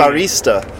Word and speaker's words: barista: No, I guess in a coffee shop it's a barista. – Oh, and it's a second syllable has to be stressed, listeners.